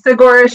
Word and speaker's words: Saguaro: And this race is was called the Saguaro, is it Saguaro Showdown Backyard Ultra? Is that how it's pronounced? – Yeah, Segura Showdown.